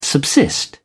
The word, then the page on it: subsist: British English